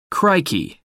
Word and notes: crikey: American English

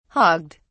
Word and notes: hogged: British English

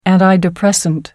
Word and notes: antidepressant: American English

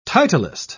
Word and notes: titlist: British English

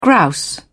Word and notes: grouse: British English

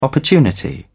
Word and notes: opportunity: British English

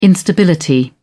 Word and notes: instability: British English

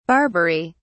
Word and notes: barbery: British English

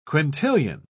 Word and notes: quintillion: British English

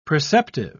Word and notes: preceptive: British English

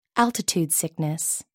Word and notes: altitude sickness: American English

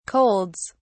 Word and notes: colds: British English

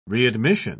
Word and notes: readmission: British English

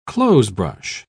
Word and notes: clothesbrush: British English

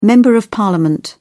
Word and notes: member of parliament: British English

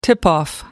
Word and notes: tip-off: American English

